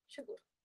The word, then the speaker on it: Xacur: es Natalia X A C U R, Shakas X A C A S S S, eh, así se escribe. Y Wattpad es Natalia Shakur, pero con un guión en medio.